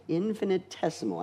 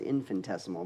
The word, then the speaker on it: infinitesimal: Infinitesimal. I thought it was infinitesimal, but it's actually infinitesimal.